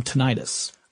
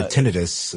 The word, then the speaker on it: tinnitus: also, I thought I'd ask you, you're familiar with the term tinnitus. – Oh, yes. Or tinnitus, as some people pronounce it.